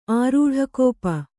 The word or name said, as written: ಆರೂಢಕೋಪ